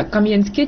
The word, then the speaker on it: Кам'янське: Столичний історик Володимир В'ятрович переконаний, що правильний наголос на останньому складі. З ним не згодна дніпродзержинська колега Наталія Буланова. Вона впевнена, що наголос топоніму на першому складі «Кам'янське» підкреслюватиме його семантику.